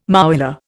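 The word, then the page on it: mouillé: Indian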